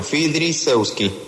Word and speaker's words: Fridzenski: Se pronuncia con F-Y primero, Fridzenski.